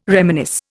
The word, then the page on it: reminisce: Indian